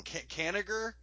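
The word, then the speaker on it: Kanigher: Kaniger? Is that how you pronounce it? – Yeah. – I don't.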